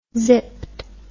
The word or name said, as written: zipped